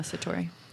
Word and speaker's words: Satori: Some claim to experience an instant shift, often referred to as a Satori moment. Is that how you say it? – Uh-huh, Satori.